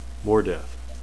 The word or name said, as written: mordeth